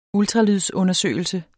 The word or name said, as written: ultralydsundersøgelse